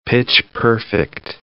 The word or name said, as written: pitch-perfect